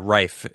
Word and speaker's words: Rife: Yeah, Rife is how you pronounce it, correct? Yeah, that's an interesting dive.